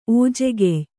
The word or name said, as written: ಓಜೆಗೆಯ್